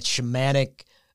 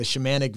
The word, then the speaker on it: shamanic: The shamanic vision. Am I saying that right? – Yeah, you can say shamanic. Shamanic, yeah. You know, like a shaman.